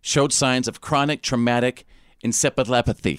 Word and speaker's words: encephalopathy: Encephalopathy.